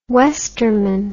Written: westerman

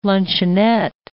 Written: luncheonette

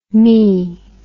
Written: mee

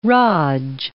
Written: raj

